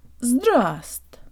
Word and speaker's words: здравст: The first V is silent in this word, so we only pronounce the S and the T. Джо танк липс. Тра, тра, тра, здраст.